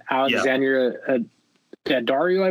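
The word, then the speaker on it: Alexandra Daddario: And this one had Alexandria Daddario, I believe is how you say her name.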